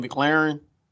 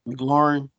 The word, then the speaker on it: McLaurin: I do like Terry, how you say his name, McLaren? McLaurin.